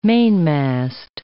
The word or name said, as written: mainmast